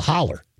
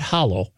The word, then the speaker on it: holler: And he grew up in Jackson, Kentucky, in the holler, not hollow. It's pronounced holler.